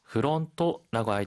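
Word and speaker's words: 프론토 (フロント): 프론토라고 할 때, F 발음이기 때문에, 프가 아니라, 흐라고 발음해야 합니다.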